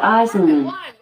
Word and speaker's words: Eisenman: I pronounce that Eisenman, but I'm always working on how I say things and I might not have it right.